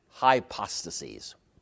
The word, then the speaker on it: hypostases: And then we're going to say that there are three hypostases. H-Y-P-O-S-T-A-S-E-S. Hypostases. That's the plural. If you want the singular, put an I in the last syllable instead of the E. One hypostasis, three hypostases. Like hypothesis and hypotheses.